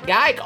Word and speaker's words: Geick: And, y'all, as my good friend, the old gal, used to say, his name rhymes with dyke. Big Fatty, is it, trike? No, it's Pat Geick.